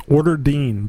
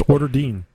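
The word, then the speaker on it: Oorderdeen: I think it's Orderdeen, just because it's O O R D E R D E E N or D E N E. So I think it's Orderdeen.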